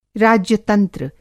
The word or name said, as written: राज्यतंत्र